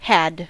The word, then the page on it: had: American English